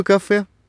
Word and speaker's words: кафе: А в конце слова «кафе»? – Произносим «э», а пишем после согласной «е».